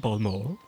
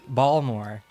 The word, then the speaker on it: Baltimore: In old Baltimore, I guess, Oh, yes, in old Balmore. – Balmore? – Of course, that's how we say it here. – Balmore. – You don't pronounce the T.